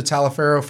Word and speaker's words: Taliaferro: It's either T-A-L-I-A-F-E-R-R-O.